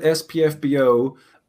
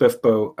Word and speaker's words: SPFBO: I pronounced it SPFBO until I met Laura Hughes and she told me it was pronounced Spiffbo, and so now I pronounce it Spiffbo because she said so.